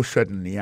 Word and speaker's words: certainly: Alors, en parlant de accent, gardez bien pour un mot certainly, accent tombé sur le premier syllabe là.